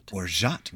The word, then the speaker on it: orgeat: And orgeat.